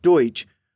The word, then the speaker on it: Deutsch: Dutch is actually a mispronunciation of Deutsch, which is German for, well, German.